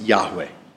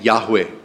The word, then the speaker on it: Yahweh: In Hebrew, I am was spelled YHWH. So it's probably pronounced Yahweh.